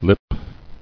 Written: Lipless

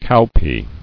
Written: Cowpea

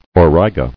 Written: Auriga

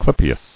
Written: Clypei

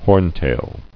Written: Horntail

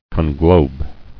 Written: Conglobing

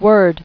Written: Worded

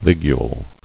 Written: Ligule

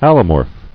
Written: Allomorphism

